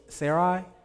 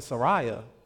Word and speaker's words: Sarai: So Sarai? Yeah, see, I was pronouncing Saraya.